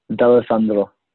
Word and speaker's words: D'Alessandro: And they brought in D'Alessandro. I don't know if I'm pronouncing that right. You're the apostrophe name expert. Is that right? – D'Alessandro. – Yeah, nice.